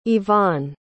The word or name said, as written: yvonne